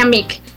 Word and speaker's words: MIK: Es M-I-K, así se escribe y se pronuncia MIC.